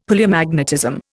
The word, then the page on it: Palaeomagnetism: Indian